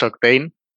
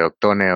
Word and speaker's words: Oct8ne: o en teoría es, es, Octane, pero bueno, si mucha gente dice Octone, Octane.